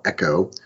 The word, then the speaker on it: Exo: So last week, a company called Echo, which is spelled EXO, landed $220 million in a Series C, which is very large for medtech financing.